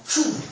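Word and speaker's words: chou (שוב): C'est un petit verbe, tout court, trois lettres simplement, chou. Ça, c'est la prononciation.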